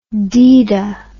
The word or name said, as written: deeda